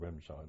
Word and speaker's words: Remscheid: Never heard of the place called Remscheid at all.